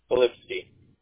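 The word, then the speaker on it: Filipskiy: Polipsky. – Okay, can you spell that for me? – Yes, sir. F as a Frank, I-L-I. P as a Paul, S as a Sam, K-I-Y.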